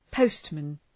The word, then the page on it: postman: ‘pəʋstmən